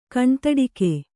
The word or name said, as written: ಕಣ್ತಡಿಕೆ